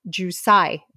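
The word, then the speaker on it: Jiu cai (韭菜): And then inside, traditionally, they always have jiu cai. May pronounce it? – Jiu cai. – Jiu cai. Which is a hard word to pronounce, as you can tell.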